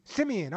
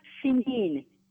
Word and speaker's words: Simeon: Simeon. – Okay, Simeon. That's easy for me. It's like Simeon, like San Simeon, right? – Well, it's pronounced Simeon.